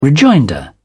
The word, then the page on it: rejoinder: British English